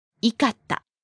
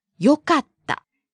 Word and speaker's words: よかった: The past form of this is not? – いかった. – But rather? – よかった. E becomes よかった for informal past. The formal past would be よかったです.